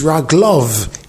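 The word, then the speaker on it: Raglov (רגליו): So the Kasiv is Raglov, his ways. The Kri is Raglai, my feet.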